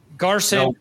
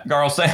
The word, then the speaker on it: Garson: I miss Sam Garlson, too. Did I say his name right? – Garson. – Yeah, Garson. I said it backwards there.